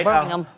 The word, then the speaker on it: Birmingham: You got to say it right. – Birmingham.